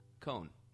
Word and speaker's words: Cohn: I think that's how you pronounce it. Cohn. C-O-H-N. – I'd go with Cohn.